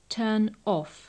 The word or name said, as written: turn off